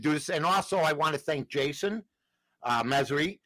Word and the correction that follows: Mesrahi: Is that how you pronounce his name? – Mesrahi. Jason Mesrahi.